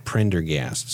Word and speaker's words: Prendergast: It is, my last name, again, is Prendergast.